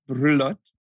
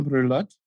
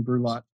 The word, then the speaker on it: Brulotte: Adam spells his last name B-R-U-L-O-T-T-E. Will you pronounce your last name for us, Adam? – Yeah. So it's a French last name, and so it's pronounced Brulat in French. So Adam Brulat. But my, if in English, it's fine, Adam Brulat.